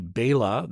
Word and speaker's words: Bela: Mistakenly call him Bela. It should be Bela. That is the correct pronunciation.